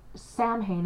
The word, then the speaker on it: Samhain: But yeah, so Samhain is actually pronounced Samhain.